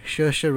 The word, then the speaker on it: Saoirse: Instead, we have Cynthia Erivo for Harriet, Scarlett Johansson for Marriage Story, Saoirse Ronan for Little Women, who wasn't Nice pronunciation, by the way.